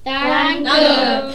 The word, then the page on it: danke: german